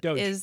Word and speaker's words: DOGE: Let me tell you, I'm so this Doge thing, or is, am I saying it right? – Doge.